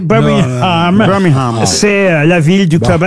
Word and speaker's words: Birmingham: Birmingham, en tout cas. On ne doit pas prononcer le G, hein, il paraît.